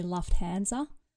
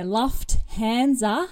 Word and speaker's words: Lufthansa: I do think we have the right answer, but I just want to correct the pronunciation. It's actually Lufthansa.